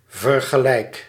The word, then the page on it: vergelijk: /vǝɾ.ɣe.lɛk/